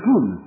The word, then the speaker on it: POUM: Or, le POUM et OUM, Parti Ouvrier d'Unité Marxiste, et ils vont prononcer Poum, c'est ça, c'est dérisoire.